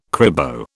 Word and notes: cribo: British English

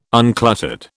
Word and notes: uncluttered: British English

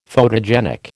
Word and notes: photogenic: American English

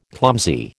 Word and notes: clumsy: American English

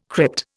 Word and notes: crypt: Indian

